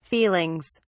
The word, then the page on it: feelings: British English